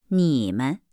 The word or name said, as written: nimen